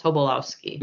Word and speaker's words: Tobolowsky: Tobolowsky.